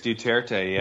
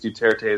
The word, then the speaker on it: Duterte: Is that I think it's Duterte, yeah.